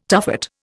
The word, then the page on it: tuffet: Indian